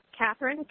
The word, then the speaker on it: Kathryn: Catherine, K-A-T-H-R-Y-N. And the last name is Blue, and it's spelled B-L-E-W.